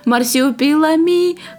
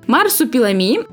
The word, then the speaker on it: Марсупилами: А в этом виновата заставка мультсериала, где Марсиупилами, хуба, хуба. – Марсиупилами, hey Frank, in the jungle, hey Mac. Хуба! Хуба! – Но ну, там его так и называли, поэтому я ну, произносила не очень правильно. Марсупилами – это вымышленное, чрезвычайно редкое животное, обитающее в вымышленной стране Колумбии.